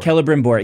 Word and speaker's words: Celebrimbor: Celebrimbor.